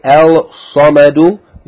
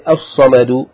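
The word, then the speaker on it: as-samadu (الصمد): You don't say al-samadu, you say as-samadu, and like this.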